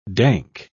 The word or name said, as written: dank